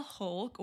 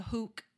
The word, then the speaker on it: Hulk: And then Augie dressed up as the Hulk, or the Hook is how he pronounced it.